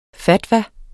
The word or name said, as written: fatwa